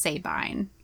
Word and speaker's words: Sabine: It is generally pronounced Sabine.